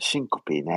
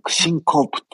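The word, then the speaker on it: syncope: そうですよ、はい、シンコピーね。シンコープって読んじゃうな。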